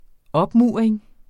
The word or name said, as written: opmuring